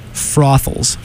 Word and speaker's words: Frothels: That name's Brothels? – Frothels. Rhymes with brothels. – Frothels. – But it's Frothel. – With an F-R-O-T-H, I think.